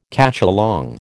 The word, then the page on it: cacholong: American English